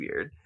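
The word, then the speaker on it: Urðr: So it's three sisters, Weird, which is spelled U-R-D-R, but pronounced Weird, is usually sort of the leader, and it translates to fate or sometimes the past as well.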